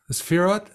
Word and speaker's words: sefirot: So do you want to start to go through the sefirot? – Yeah. – Am I saying that correctly? The sefirot? – Sefirot. Got it. You're doing good, brother. – Sefirot. – Sefirot. Okay.